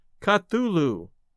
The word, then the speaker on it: Cthulhu: I really enjoyed the Mark Twain stories and the Cthulhu stuff. And by the way, I've always pronounced it Cthulhu.